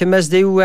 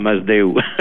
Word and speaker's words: Masdeu: Oui, monsieur Jacques Masdeu Arus. – Oui, voilà, vous prononcez en catalan là, Masdeu.